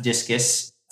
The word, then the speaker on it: Disqus: And eventually I joined a company called Disqus, sometimes pronounced Discus.